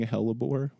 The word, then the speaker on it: hellebore: Living hellebore.